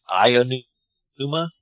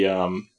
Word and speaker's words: Aonuma: It seems like sometimes they say, like, Ionuma? Pete-numa? – It would be, Aonuma. – Aonuma? – A standalone is A, and O is O.